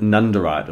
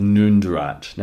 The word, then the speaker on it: Nundurite: The next brown stone I want to talk about is called Nundurite, or sometimes pronounced Noondurite.